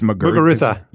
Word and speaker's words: Muguruza: And then I don't even know how to pronounce the French Open winner's name. It's Muguruza. – Yeah. Okay.